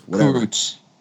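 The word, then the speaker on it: Kurucs: The Rockets are getting Victor Oladipo, Dante Exum, Rodions Karus. I don't know if I said that right. Karuks. – Karuks. – Yeah, thank you.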